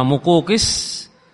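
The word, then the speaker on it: Muqawqis: Seorang penguasa Mesir, namanya apa? Muqukis. Bacanya Muqukis. Seorang yang bernama Muqukis, penguasa di negeri Mesir, yang memberikan hadiah himar tadi kepada Rasulullah SAW, namanya Ufair.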